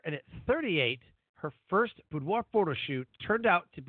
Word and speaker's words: boudoir: still get to make it on the show, which is super cool, because today we're going to talk about, and I should know how to pronounce this, boudoir, right? – Good job, yes. – I figured it's like boudoir, something like that, right?